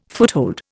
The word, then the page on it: foothold: Indian